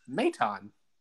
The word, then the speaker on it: Maton: Real briefly as well, I did just find the Phillies pronunciation guide for the year, and it is Maton. – Okay, Maton. So I was wrong.